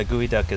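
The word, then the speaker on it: geoduck: Yeah, it's like geo duck, but it's spelled gooey. Mm. Or it sounds like gooey.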